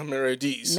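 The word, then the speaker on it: Merediz: Is it Olga Meredes? Meredes? – Olga Meredes. – Meredes, okay.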